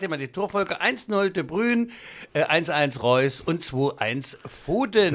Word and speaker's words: De Bruyne: Wegen De Bruyne, der alte Witz. – Wegen De Bruyne. Das ist doch, ich habe es niederländisch ausgesprochen.